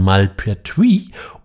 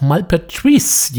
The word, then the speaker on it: Malpertuis: Wir sprechen von Malpertuis oder auch Malpertuis, je nachdem, ob man es französisch oder flämisch aussprechen möchte.